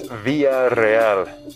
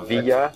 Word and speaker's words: Villareal: It's Villareal. – Villareal. – Yeah, break it into two names, if you will. Villareal.